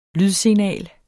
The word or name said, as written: lydsignal